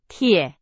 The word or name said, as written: 铁